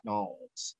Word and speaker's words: New Orleans: If y'all drive through Louisiana on I-10, okay, Louisiana, we're going to New Orleans. You say it like that, New Orleans.